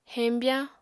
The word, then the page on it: heñbyañ: apatani